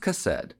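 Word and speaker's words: chesed (חסד): which is chesed. Chesed. That's spelled C-H-E-C-E-D. Also, alternate spelling is H-E-S-E-D, but it's pronounced chesed. Chesed.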